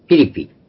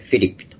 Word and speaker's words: フィリピ: はい、それではですね、ちょっと、いろいろありましたが、今日のところは、このピリピ、以前はピリピという日本語でしてたんですが、原文の発音、言語の発音に近いようにとフィリピと、とても言いにくいので、言語に近くなくて、例えばイエスースですね、言語ではイエスのこと、イエスース、クリストスですけども、英語ではジーザスでやって、全然いいでも何でもないわけですね。